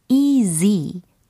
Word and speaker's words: easy: 이지가 e 아니고, easy. z, z.